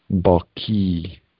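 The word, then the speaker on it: Ba'ki (الباقي): The 16th one has an elongation in the second syllable. Ba'ki. Q sound is like a hard K sound.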